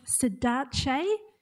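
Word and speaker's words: saudade: It's pronounced Sadace, and it's spelt S-A-U-D-A-D-E. S-A-U-D-A-D-E. Testing my memory.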